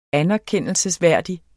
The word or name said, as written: anerkendelsesværdig